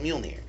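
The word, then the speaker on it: Mjölnir: It's called Mjolnir. And that's how you pronounce it, because I'm a nerd.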